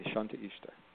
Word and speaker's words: Shanti Ishta: I like this word, Shanti Esta. It reminds me of the Shanti in Sanskrit, which means peace. – It's Shante. It's spelled a little differently. It sounds very similar. Ishta, Shanti Ishta.